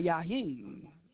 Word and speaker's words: Jaheim: So I'm going to play this track called Just In Case by Yaheem. And some pronounce it Yaheem, J-A-H-E-I-M.